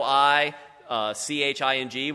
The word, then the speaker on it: I Ching: spelled capital I, C-H-I-N-G, which you would think pronounce. Do you ever pronounce that I Ching, not knowing what? Okay, I did too. So yeah, it's actually I Ching, and that's super confusing.